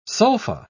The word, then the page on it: sulpha: British English